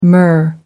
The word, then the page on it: myrrh: American English